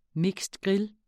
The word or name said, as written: grill